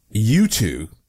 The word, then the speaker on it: Yutu: China's U2, and that's spelled Y-U-T-U, China's U-2-2